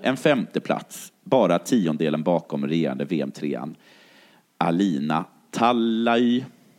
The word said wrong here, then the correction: Talay: Tallej. Mm uttalas.